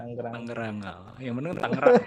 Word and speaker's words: Tangerang: Tangerang itu cara bacanya Tangerang apa Tanggerang sih sebenarnya? Soalnya lo tadi bilangnya Tangerang gitu kan, sedangkan gue taunya Tanggerang gitu. Yang bener yang mana? sebagai lokal, iya, orang sebagai lokal representatif. Orang orang Tangerang sendiri bacanya Tangerang.